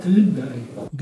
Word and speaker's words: Thunberg: Greta Thunberg.